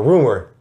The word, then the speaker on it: RMR: RMR, Rumor. Artist by the name of Rumor, R&B singer Rumor. – Oh, that's how you say it. I thought Rumor. Okay, yeah, Rumor.